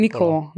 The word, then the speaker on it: Николо: Николо. Нали така, да, е ударението, да, правилно го каза.